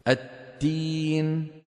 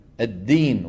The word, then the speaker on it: الدين: نحو التين. التين. – مالك يوم الدين وليس التين.